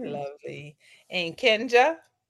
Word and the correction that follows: Kenya: Hi, it's Kenya. The J is silent, dog.